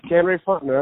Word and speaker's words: Fontenot: I feel like I did some research and the name, and I'm going to get this name wrong too, like Kenray Fontenot? – Yep, Kenray Fontenot, that's correct.